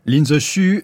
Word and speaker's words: Lin Zexu: Lin Zexu,